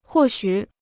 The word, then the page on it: 或许: huò xǔ